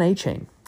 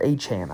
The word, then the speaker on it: Achane: Devon Achane, or Achane, I guess I believe it is technically pronounced, for the Dolphins, has been really, really good when he has gotten to double-digit carries.